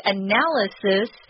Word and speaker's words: analysis: And notice it's a different pronunciation. We use a Z sound, analyze. And analysis is the noun form of the word, and we use a S sound in that instead.